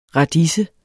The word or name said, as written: radise